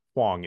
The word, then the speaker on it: Huang: But for our actual viewers, Huang is the pronunciation.